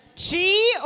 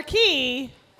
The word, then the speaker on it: qi: It's either chi or key, but it's QI.